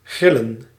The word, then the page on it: rôtir: [ʁo.tiʁ]; /ʁɔ.tiʁ/